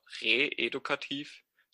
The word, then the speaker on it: re-edukativ: Deswegen auch reedukativ, nicht reedukativ, habe ich immer so ausgesprochen. Satz 2 ist re-edukativ. Genau.